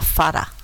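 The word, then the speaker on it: Fará (פרעה): Da mesma forma, a lista de nomes femininos inclui uma, um cuja ortografia em hebraico é idêntica a é, Faraó, mas que é pronuncia, pronunciada Fará.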